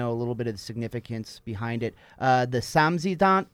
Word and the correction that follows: samizdat: and the Tamzidant. Did I pronounce that correct? – Close. – Okay. – Now I'm all like, how do I say it? Samizdat, so which means basically self-publishing.